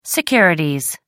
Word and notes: securities: American English

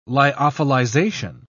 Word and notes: lyophilization: British English

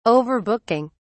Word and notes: overbooking: British English

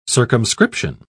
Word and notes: circumscription: British English

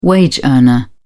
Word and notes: wage earner: British English

